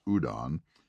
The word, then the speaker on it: Houdon: You know, if you want to see his work, just Google it, Houdon, pronounced Houdon.